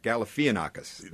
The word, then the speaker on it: Galifianakis: I think that's the way most people pronounce it, although there's an extra I in there, so I think it's Zach Galifianakis.